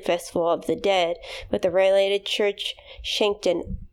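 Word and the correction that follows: sanctioned: Come on. Just don't start it with a sh. You put the sh in the second syllable.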